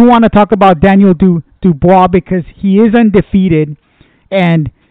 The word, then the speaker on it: Dubois: Daniel Dubois, and I hope I'm saying that correctly.